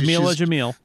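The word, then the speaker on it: Jameela Jamil: What yeah, Jamila Jamil. Jamila yep. Jamil, right? That's what I can remember. Jamila Jamil, this is the first – serious acting job she had.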